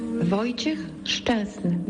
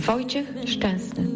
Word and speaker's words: Wojciech Szczęsny: Se pronuncia Wojciech Szczęsny. Wojciech Szczęsny.